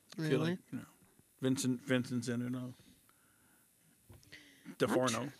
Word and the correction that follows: D'Onofrio: Is that how you pronounce his name? DeForno? – D'Onofrio. – Sorry. Yeah. Vincent D'Onofrio's in it.